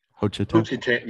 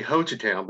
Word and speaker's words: Hochatown: First of all, is that how you pronounce the name of the town? – Hochatown. – Yes, say Hochatown, because if you don't, you say it differently, everyone up here will know you're a tourist.